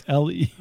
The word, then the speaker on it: Lefebvre: I don't know how you say his name. It's Lefebvre. It's a French name like mine.